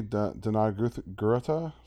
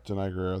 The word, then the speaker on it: Danai Gurira: The girl who plays Okoye, Danai Gurira. Sorry, I'm... – Danai Gurira, thank you. Butchered that name.